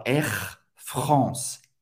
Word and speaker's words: Air France: si já voou com esse avião da Air France mais une fois, vous vai prononcer esse R bem, uh, bem garganta. R, France. R, France. Hein, France, A-N, mais uma vez, tem o som do ON. R, France. Não vai pronunciar o E no final. Não é r France, r France.